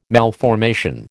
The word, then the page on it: malformation: American English